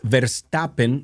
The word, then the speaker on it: Verstappen: En la Fórmula 1, Max Verstappen, lo dije bien, Sí, sí, sí.